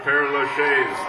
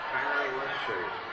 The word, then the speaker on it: Père Lachaise: If you ever get to Paris, get to that Père Lachaise. P-E-R-E-L-A-C-H-I-S-E, Lachaise. – How do you say that in Texan? Père Lachaise.